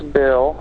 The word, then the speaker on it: Bill: It's Bill. I get Daryl all the time. – How do you spell it? – B-I-L-L, like a dollar bill.